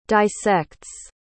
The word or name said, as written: dissects